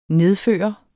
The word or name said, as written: nedføre